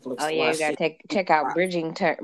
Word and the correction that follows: Bridgerton: Bridging Turn, Bridger Turn. I can't even pronounce it. It's good as hell. Mm. But yeah, that's whatever the hell that is, Bridgerton. Yeah, Bridgerton. I don't, I never seen that word.